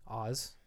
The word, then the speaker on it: Ahz: Oz is how we think it's pronounced, and it's like spelled A-H-Z, right? So it's Oz.